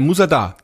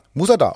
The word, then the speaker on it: Musa Dagh: Und äh, meine erste Bitte wäre, diesen Bandnamen einmal für mich auszusprechen. Äh, Musada. Musada, okay, so hätte ich es auch ausgesprochen.